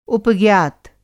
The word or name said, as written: उपज्ञात